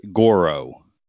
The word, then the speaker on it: Goro: My answer was going to be, I don't even know if I'm pronouncing it right, Goro.